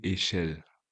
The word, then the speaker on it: Eshele: Wie wird der Nachname wirklich ausgesprochen? – Manasseh Echel.